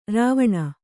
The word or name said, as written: ರವಣ